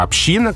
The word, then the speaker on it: община: То есть вот это направление движения идет в сторону ударения «община». И поэтому в одних словарях даны оба варианта, а в других самых строгих, которые на нас с вами ориентированы, на работников СМИ, дается только «община» как такой эталонный, классический, образцовый вариант во всех случаях, кроме «палаты общин».